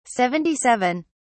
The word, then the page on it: 77: British English